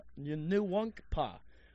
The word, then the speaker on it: Nwankwo: Nwankwo. That is exactly how you say it.